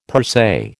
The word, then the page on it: per se: American English